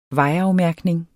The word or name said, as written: vejafmærkning